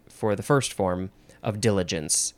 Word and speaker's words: diligence: I pronounce it wrong. It is diligence or diligence. This is French, and it literally means haste.